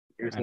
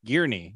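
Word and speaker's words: Guernsey: It's pronounced, where'd that go? Gearsney. Not okay. Gearney or Gearsney. Not Gearsney. Gearney. Yeah.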